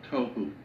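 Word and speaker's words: tohu (תהו): Pronunciation, it would be T-O-E hyphen W-H-O, tohu. That's not the way it's spelled in Hebrew, but that's the way it's pronounced. T-O-E W-H-O, tohu. Vohu would be V-O-E W-H-O, tohu, vohu, waste, desolation.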